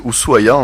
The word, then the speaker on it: Ushuaia: Alors, Ushuaia, vous le prononcez très bien, c'est Ushuaia en réalité, hein, comme prononcent les locaux.